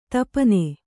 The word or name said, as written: ದೀಪನ